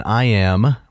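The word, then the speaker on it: iamb: So we should specify or stipulate for the audience that an iam, which is spelled with a B, right, mm-hmm.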